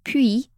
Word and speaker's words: puits: Puy, as in his name, is spelled P U Y, but it sounds exactly like puits, P U I T, which is French for well, as in water well.